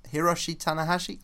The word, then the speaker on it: Hiroshi Tanahashi: on at number eight was a new entry, Tanahashi Hiroshi Hiroshi Tan Hiroshi Tanahashi. I've butchered Hiroshi that. Tanahashi. There you go.